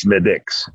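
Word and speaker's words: Smithwick's: And it looks like it's spelled it's spelled with a Wix. – Yeah. – But it's pronounced Smittix.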